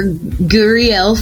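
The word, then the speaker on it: Gurriel: Yes, Dr. Guriel Friend is the pronunciation after a gentleman by the name of Yuli Guriel.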